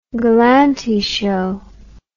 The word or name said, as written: galanty show